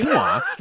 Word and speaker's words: quinoa: That's quinoa. That's how you, yeah, it's pronounced quinoa.